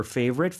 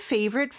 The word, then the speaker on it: favorite: F 발음은 윗니를 아랫입술에 살짝 댄 상태에서요. 푸라고 발음하는 것처럼 바람을 내뿜는 소리예요. 한번 시범 보여주시겠어요? F 발음은? 바로 저 소리입니다. – Favorite. – Favorite. 이죠. 그래서 바람을 내뿜는 거를 익숙해지셔야 돼요. 우리말에는 사실 이 바람을 내뿜는 소리는 잘 없거든요. 그래서 favorite food.